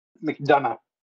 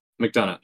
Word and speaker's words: McDonough: McDonough. – McDonough.